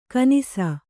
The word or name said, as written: ಘನಿಸು